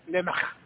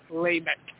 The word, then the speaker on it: Lamech: You've got good pronunciation, although it is Lamech, not Lamech.